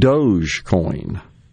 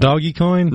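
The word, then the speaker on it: Dogecoin: This one, you see this mispronounced all the time, Doge coin. – It's not doggy coin? – Nope. Or Doja. How about this one? Eilish. The singer, Billie Eilish. E-I-L-I-S-H.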